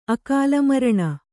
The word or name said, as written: ಅಕಾಲಮರಣ